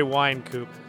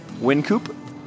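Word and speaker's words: Wynkoop: I'm going to say Wine Coop. – You're going with wine, not win. – Yeah, wine. – Win Coop? – You're going with win.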